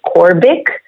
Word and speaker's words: Corbic: and the last name is Corbic.